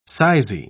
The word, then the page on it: sizy: British English